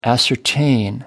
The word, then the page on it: ascertain: American English